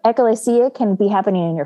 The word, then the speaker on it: ekklesia: It's the ekklesia. – Yep. Good. I like that. – Mm-hmm. And that is the end of my ancient Greek. – You did a great job pronouncing that word.